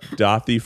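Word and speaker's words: Daði: I recommend you look up Dothi Freyr, and you would actually spell it D-A-D-I, because that's actually an Icelandic letter that we don't have.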